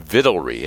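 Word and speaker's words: victualry: Vittlery. Have you ever used that word? V-I-C-T-U-A-L. Like, it's pronounced vittles. Vittlery.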